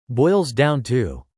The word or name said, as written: boils down to